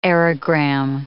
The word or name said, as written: aerogramme